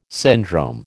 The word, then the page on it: syndrome: American English